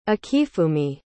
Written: akifumi

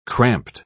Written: cramped

